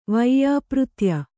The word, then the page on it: ವೈಯಾಪೃತ್ಯ: vaiyāpřtya